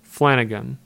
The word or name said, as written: Flanagan